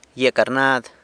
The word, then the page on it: gyia carnad: sanlucasquiavini